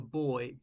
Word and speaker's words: buoy: The boy, BUOY, as in buoy, as in a flotation device. I know in American it's pronounced boy.